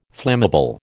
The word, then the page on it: flammable: American English